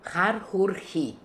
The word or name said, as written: Harhoory